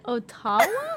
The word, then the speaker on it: Ottawa: O-T-T-A-W-A? Tell me what you just said. – Ottawa? How do you say it? – Ottawa, Canada, dude. – I would imagine that would be spelled O-T-T-O-W-A. – It's like a Native American name, or a Native... – Oh. – Right? – Ottawa? – Ottawa. – Use an accent on the O, then. Or on the A? – It's not Spanish.